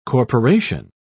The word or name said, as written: corporation